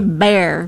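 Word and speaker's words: Bexar: San Antonio area. They say Bear. – San Antonio. Bear Behar. County. Well, that's how it was originally pronounced. In 1836, Battle of Alamo, it was pronounced Behar. Bear. – Well, that's the Spanish name, yeah, Behar.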